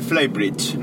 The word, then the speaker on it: Flybridge: Os voy a hablar del Flybridge. No sé si lo conocéis. Eh, se pronuncia y se escribe como fly de volar y bridge de puente.